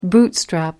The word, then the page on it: bootstrap: American English